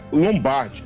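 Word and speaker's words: Lombaerde: Isso, se escreve Lombaerte. – É, Lombaerte. – Mas a pronúncia Lombardi.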